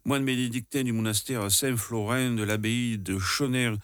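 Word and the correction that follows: Schönau: Alors là aussi, Chonau.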